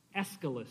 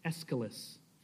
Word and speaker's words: Aeschylus: Next, Aeschylus. That's how you pronounce that. Aeschylus.